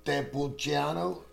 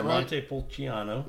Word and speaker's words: Montepulciano: Montepulciano? – Yeah, Montepulciano.